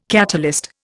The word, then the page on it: catalyst: Indian